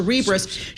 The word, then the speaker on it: Cerebras: How do you say your name? Like Cerebral. Yes. So Cerebras shares soaring.